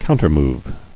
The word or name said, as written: Countermovement